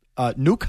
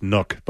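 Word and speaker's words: Nuk: Nuke Hopkins? – Yeah, but I actually believe it's pronounced Nook.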